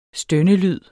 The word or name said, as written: stønnelyd